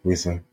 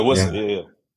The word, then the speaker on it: Wissa: How do I pronounce it? Is it Wissa? – Yeah, Wissa. – Yeah, Wissa.